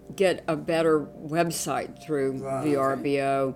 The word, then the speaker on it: Vrbo: And Now, when I was trying to find your place, or when I was trying to find a place, I was on Vrbo. I guess I'm pronouncing it right. VRBO. – Mm-hmm. Oh, yeah. VRBO.